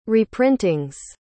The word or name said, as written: reprintings